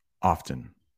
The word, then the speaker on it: often: Yeah, the T is a little silent, but notice how I said it. Oven often. And it's almost like a D, often.